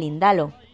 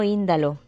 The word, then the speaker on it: Indalo: Famosa es también la figura del Indalo, que no Índalo, que llevo toda la vida pronunciándolo mal.